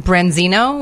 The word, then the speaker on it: branzino: So I was on vacation, and Branzino was the fish.